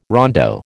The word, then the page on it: rondo: American English